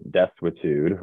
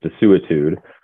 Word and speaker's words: desuetude: There's an old judicial doctrine called desuetude, or sometimes it's pronounced desuetude, that, that dates back to Roman times, so it's very old.